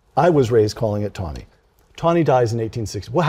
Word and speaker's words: Taney: After the, ju- the death of Chief, ju- Chief Justice Roger B., it's pronounced T A N E Y. I say Tawny, but other people say Taney. – Um, well, my father sailed on the U.S. Coast Guard cutter Roger B. Tawny during the Second World War, and he always pronounced it Tawny.